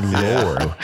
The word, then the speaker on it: Naylor: I mean, people look at it and they think because it's spelled like Taylor, so they try and pronounce it that way, but it's not pronounced like Taylor. It's pronounced like Naylor. Yeah, Naylor.